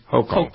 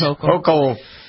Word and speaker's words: Hochul: Hochul. – Yep. It's a hoax. Hochul.